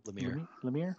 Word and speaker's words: Lemire: It felt like Giffen and Lemire, or Lamar, or however you pronounce his name. What is it? – Lemire. – Yeah. Jeff Lemire.